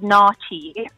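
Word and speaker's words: naartjie: But you actually, obviously, South African accent, you would pronounce it nachi.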